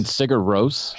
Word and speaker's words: Sigur Rós: Oh yeah, Sugar Raw. I can't say this word. Aegis Brunnerbra. I don't know how you say that. Aegis. – You mean Sigur Rós? – Sigur Rós.